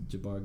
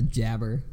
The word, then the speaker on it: Jabbar: Used to say Jabber. When I was a kid, I'd be like, hey, I got Jabber Gaffney on my Madden team.